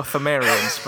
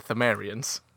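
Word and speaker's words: Pthumerians: The Pothamarians, but the P is silent, so it's the Thimerians.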